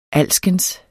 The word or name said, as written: alskens